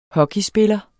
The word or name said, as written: hockeyspiller